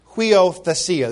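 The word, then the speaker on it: huiothesia (υἱοθεσία): Now, you might right away you say, well, that's a U, but there's a, you know, in the Greek, when a U starts a sentence, we often put an H next to it so you can pronounce it. I mean, this is hard enough to pronounce with three vowels, and you see the U and the I and the O or the Iota and the Omicron, it's huio, huiothesia. This is a TH, theta, huiothesia.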